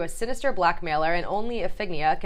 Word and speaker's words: Iphigenia: I think it's just Iphigenia, yeah. – Iphigenia? Okay. In my head, I pronounce Iphigna, which makes no sense.